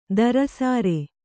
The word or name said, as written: ಧಾರಾಸಾರ